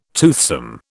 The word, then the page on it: toothsome: British English